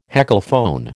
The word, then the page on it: heckelphone: American English